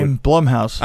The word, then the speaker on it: Blumhouse: Is it Blumhouse or Bloomhouse? Because I hear people say Blumhouse now. I don't know. Okay, Blumhouse.